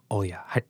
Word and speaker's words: Olya: Olya. You say it how you say it? – Olya. – Olya. – Yes. – Olya. Olya. It's almost like you don't pronounce the L.